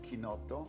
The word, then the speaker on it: chinotto: And it's basically made out of chinotto, which is a citrus that in the United States is not present.